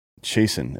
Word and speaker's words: Chasten: Is he not pronouncing the T? – Chasten is how you say that word. – Chasten. – Yeah. – It's a soft T. It's an actual word. Chasten to chasten. – Chasten is, there's no T in it, dude. – No, it's... And also, the T's kind of pronounced there. I feel like there's a T sound.